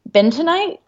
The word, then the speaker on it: Bentonite: Bentonite clay? It's literally spelled bin tonight. B E N, sorry, B-E-N. like like the man. – Tonight ITE. It's,